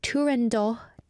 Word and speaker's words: Turandot: Firstly, I'm going to talk about Turandot, the opera. We talked about this on Culture Walk. Turandot was just in Korea recently in the summer in July, and Turandot, or the Italian pronunciation is Turandot.